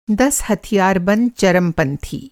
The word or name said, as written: हथियारबंद